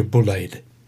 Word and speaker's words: Bulleid: Queen's commendation for Winchester's Major Bullade. and I'm sure she'll forgive me if I've pronounced her surname incorrectly. It's spelt B-U-L-L-E-I-D, and I will refer to her as Major Bullaid.